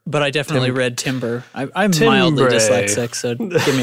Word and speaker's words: timbre: Timber, is it? How do you say that? Timber? Tambor? – I would say timber. Oh, no, you're right. – It's timbre, like the musical term.